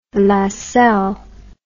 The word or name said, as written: Lassell